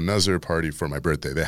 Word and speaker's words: Nazar: Yeah, but if you look on Instagram, it's just No Nuzzar, which is spelled N-A-Z-A-R.